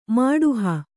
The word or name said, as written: ಮಾಡುಹ